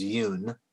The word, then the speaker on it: Yoon: My Korean name, which is Yoon. So, there you go. Yeah. – Oh, very nice. And it's with a, how do you spell that? – Y-O-O-N. – Y-O-O-N.